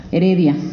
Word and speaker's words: Heredia: bueno, también tenemos La Jaula de Dios, de Rafael Ramírez Hereida. Heredia, lo dije mal, y La Vida Mental, de Henry Wallum.